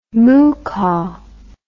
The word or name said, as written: moocah